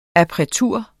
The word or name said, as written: appretur